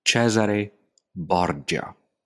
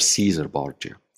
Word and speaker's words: Cesare Borgia: The most important political project to unify Italy at the time was led by Cesare Borgia or Caesar Borgia. It depends how you, whether you want the Italian or the English pronunciation.